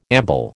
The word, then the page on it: amble: American English